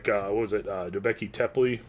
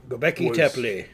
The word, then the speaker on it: Göbekli Tepe: But there are a few, like, Göbekli Tepe? – Göbekli Tepe.